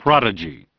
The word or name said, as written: Prodigy